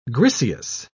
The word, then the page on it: griseous: British English